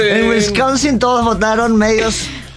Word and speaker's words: Wisconsin: Los estados que estarían faltando: Pensilvania, Wisconsin y Michigan.